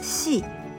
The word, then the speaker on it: し: Note that the second kana is pronounced shi and not si.